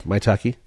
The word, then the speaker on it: Maitake: Maitake.